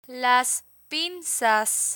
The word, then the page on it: las pinzas: Spanish